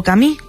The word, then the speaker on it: Camus: Narrado por nuestros compañeros de Un libro, una hora, inaugura la primera novela del escritor argelino-francés Albert Camus, o Camus, como se pronuncia en su idioma natal.